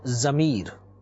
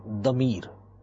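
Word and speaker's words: damir (ضمير): Oh, in Urdu we normally say it zamir, and in Arabic pronunciation we call it damir.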